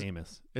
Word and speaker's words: Amis: I think it's Amis. It's Amis. British, right? – Yeah.